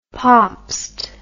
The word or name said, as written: pabst